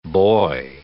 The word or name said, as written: boy